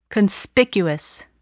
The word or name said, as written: conspicuous